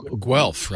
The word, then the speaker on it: Guelph: G-U-E-L-P-H. – Guelph, right? – It's Guelph. – It's in Ontario. Guelph. Yeah. It is. We have an actual Ontarian in studio, and he's saying it's Guelph. – Yes, Guelph, Ontario.